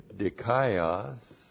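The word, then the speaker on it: dikaios (δίκαιος): Well, there is the adjective dikaios. D-I-K-A-I-O-S.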